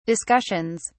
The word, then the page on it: discussions: British English